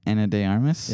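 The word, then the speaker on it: Ana de Armas: No. Say it correctly, and then I'll tell you you have the right answer. – Anna? – Anna de Armas. – Anna de Armas? – Yeah, here, I'll show you.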